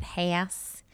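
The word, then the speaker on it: Hass: So the Haas avocado, and they pronounce it Haas.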